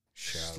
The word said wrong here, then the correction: Schlatter: Schlatter. – Yeah. – Charlie Schlatter.